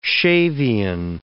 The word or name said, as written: Shavian